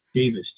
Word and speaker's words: Davis: My first name is Kent, K-E-N-T. – And your last name? – Davis, D-A-V-I-S.